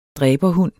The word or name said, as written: dræberhund